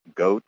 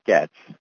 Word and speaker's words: Goetz: It was pronounced Goetz, Goetz, Goetz, but in fact, it is pronounced Getz, like gets it done.